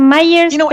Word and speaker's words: Meyers: Que es Alex, mm, creo que se pronuncia Myers, pero se escribe como Meyers, M-E-Y-E-R-S,